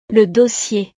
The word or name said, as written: le dossier